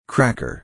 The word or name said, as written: cracker